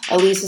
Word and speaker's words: Ilise: Elise is spelled I-L-I-S-E.